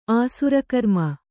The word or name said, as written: ಆಸುರಕರ್ಮ